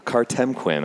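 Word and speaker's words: Kartemquin: Okay, so, by the way, I met, so Kertemplin, I always have to say it, those three syllables,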